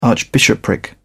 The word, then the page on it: archbishopric: British English